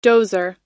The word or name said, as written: dozer